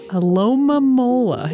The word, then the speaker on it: Alomomola: Alomomola. – Alomomola.